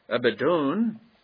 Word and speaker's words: Abaddon: So if he was going to have a name in Hebrew, it would be pronounced Abaddon.